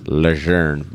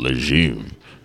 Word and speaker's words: Lejeune: Yeah, Camp Lejeune, or the correct pronunciation is Lejeune, but here in America. – The name's Dumas. – We call it Lejeune.